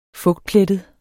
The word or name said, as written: fugtplettet